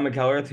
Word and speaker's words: McEllrath: Bob McEllrath. Am I saying this right? – Yeah. – Okay. I never know if I'm too Irish or not. I have to check. Bob McEllrath,